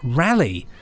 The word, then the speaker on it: Raleigh: Around here, it's pronounced Rally.